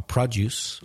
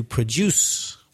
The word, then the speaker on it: produce: So the stress changes. The verb has the stress on the second syllable, produce, and the noun on the first syllable, produce.